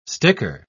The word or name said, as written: sticker